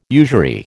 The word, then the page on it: usury: American English